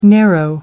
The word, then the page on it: narrow: American English